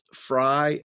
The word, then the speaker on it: Frey: Fry, and my last name is spelled F-R-E-Y.